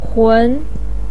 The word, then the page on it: hun: 2